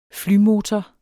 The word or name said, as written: flymotor